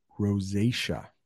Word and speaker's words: rosacea: If you've ever seen a person with skin like that, we would call that rosacea.